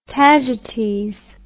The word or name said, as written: tagetes